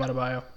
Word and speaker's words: Adebayo: Like, you got Jimmy Butler, Bam Adebayo. Is that how you say it? – Bam Adebayo. – Yeah, okay. You got it.